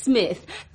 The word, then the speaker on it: Psmith: Actually, it's Smith. P-S-M-I-T-H. The P is silent, as in bath.